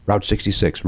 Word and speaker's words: route: How do you say R-O-U-T-E? – Root. – How do you say it? – Route. Some people say route. – Yeah. – You know what? I think I do say route. Route 66. Route. No, I say route 66.